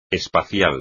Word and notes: espacial: espagnol

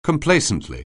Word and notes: complacently: anglais

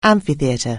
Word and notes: amphitheatre: anglais

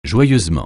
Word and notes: joyeusement: francais

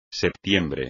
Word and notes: septiembre: espagnol

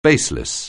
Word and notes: baseless: anglais

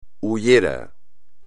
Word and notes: hullero: espagnol

